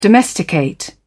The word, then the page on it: domesticate: British English